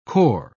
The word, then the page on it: kor: British English